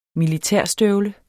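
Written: MILITÆRSTØVLE

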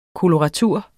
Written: koloratur

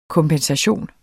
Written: kompensation

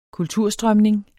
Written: kulturstrømning

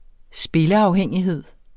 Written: spilleafhængighed

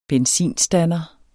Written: benzinstander

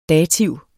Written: dativ

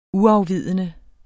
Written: UAFVIDENDE

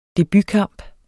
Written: debutkamp

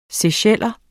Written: seycheller

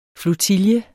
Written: flotille

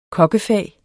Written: kokkefag

